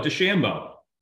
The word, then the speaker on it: DeChambeau: DeChambeau, did I say that right? – Yep, you call it DeChambeau.